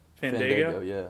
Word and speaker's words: Fandango: Fandango, right? – Fandango, yeah.